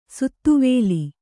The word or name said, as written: ಶಾದ್ವಲ